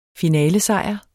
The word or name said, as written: finalesejr